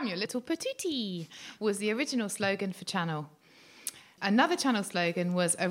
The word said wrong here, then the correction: Chanel: Is it not pronounced Chanel? – Channel. That's so, did I say channel? – You did say yeah.